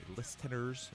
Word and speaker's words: listeners: This is my present to you, my listeners. Is that how you pronounce it? Listeners. Yeah, the silent T.